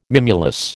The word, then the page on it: mimulus: American English